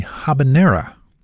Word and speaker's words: habanero: On occasion, one of those is the habanera. The correct pronunciation does not make use of the Y sound.